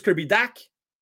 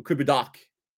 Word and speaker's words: Dach: Est-ce que vous voulez que je dise Kirby Dak ou Kirby Doc? On règle ça direct là. Comment voulez-vous qu'on le prononce? Parce que moi, je n'ai pas de problème avec ça. Moi, je peux dire Kirby Dak. dirais jamais de Kirby Dash, par exemple. Kirby Dash, ça là, ça me frise les oreilles, moi là.